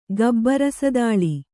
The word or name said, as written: ಗಬ್ಬರಸದಾಳಿ